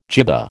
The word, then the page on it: jibba: American English